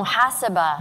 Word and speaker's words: Mahasabha: You mentioned Mahasabha. Did I pronounce it right? I didn't pronounce it right. – Yeah. – The accent's on the wrong part. Mahasabha? – Oh, Mahasabha. – Mahasabha. – Yes, yes.